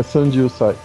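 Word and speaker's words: Sanju sai (三十歳): É, Sanju sai.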